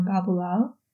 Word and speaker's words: Baboolal: Welcome to today's guest, one of my old friends, Omar Baboolal.